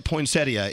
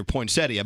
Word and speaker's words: poinsettia: If you look up poinsettia, it says clearly right there poinsettia, but it also says also can be pronounced poinsettia because dumbasses have been calling it poinsettia for all these years, and now they're saying it's okay to be stupid.